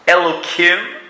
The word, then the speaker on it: Elohim: Number two, Elohim, I didn't pronounce it with a hey.